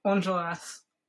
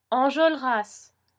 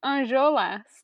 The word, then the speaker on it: Enjolras: Is the L before the R? – Enjolras. yeah. – Oh, I've been pronouncing it differently in my head. – How did you pronounce it? Oh my god, I forgot that you haven't seen these boys either. yeah, I'm not at these boys. I thought it was Anjolas, like R before the L.